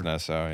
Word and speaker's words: Nassau: Nassau or Naton, or I'm not sure how it's pronounced. – Or, it's Nassau, yeah.